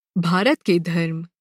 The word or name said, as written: भारत